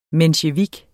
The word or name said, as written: mensjevik